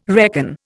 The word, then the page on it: reckon: Indian